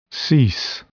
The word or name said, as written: cease